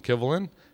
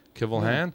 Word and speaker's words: Kivlehan: I think that's how you say it. K-I-V-L-E-H-A-N. Kivelhan. Kivelhan. Mm-hmm.